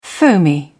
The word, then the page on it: foamy: British English